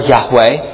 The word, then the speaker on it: Yahweh: German pronunciation would be Jehovah. I prefer Yahweh.